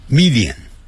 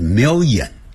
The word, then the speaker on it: million: 好，一条眼好像念成一条眼，就像一百万啊，常常会听到我们老中念成的 million，不是，在美式英语念成 million，没有眼睛 million， Italian。